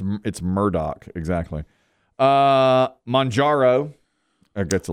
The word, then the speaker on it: Murdaugh: Here's one I know how to pronounce. Alex Murdaugh. It's Murdaugh. It's not, it's spelled M-U-R-D-A-U-G-H. So but, I would say Murdaugh. And my wife's like, don't you dare.